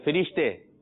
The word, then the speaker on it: فرشتے: اور درست تلفظ ہے اس کا فرشتے.